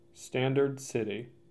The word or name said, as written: Standard City